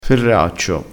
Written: ferraccio

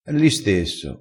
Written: l'istesso

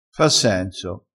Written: fa senso

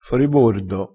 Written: foribordo

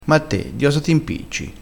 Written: impicciassi

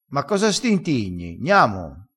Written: stintigna

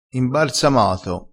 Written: imbarsamato

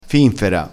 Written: finfera

